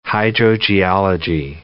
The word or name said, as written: hydrogeology